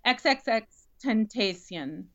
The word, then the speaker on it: XXXTentacion: XXXTentacion? – XXXTentacion.